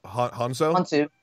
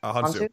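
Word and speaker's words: Hounsou: Also, I can never pronounce his name. I feel terrible. Digimon Hanzo? – Hansu.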